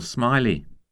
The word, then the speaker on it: Smiley: Paul Smiley. – How do you spell that? – S-M-I-L-E-Y.